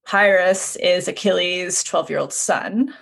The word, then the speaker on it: Pyrrhus: But then, how do you say his name? Pyrrhus? – Pyrrhus. – The son? – Yeah, the worst. Yeah, not Paris.